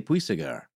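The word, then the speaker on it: Puységur: Lane was familiar with the hypnotic treatment work of the Marquis de Puysegur. Did I say that right? – Yeah, you did, actually.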